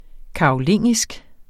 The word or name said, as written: karolingisk